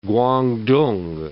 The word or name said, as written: Kwangtung